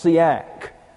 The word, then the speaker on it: siach: In Psalm 119, verse 48, we have a synonym, and it looks like this, S-I-A-C-H, pronounced Siak.